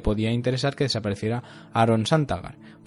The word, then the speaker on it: Santagar: Sasgar que era el, creo que lo he dicho bien, es que tiene un nombre un poco, Aaron Santagar, perdón, era el, herrero eh, de, la, de la Fortaleza Roja.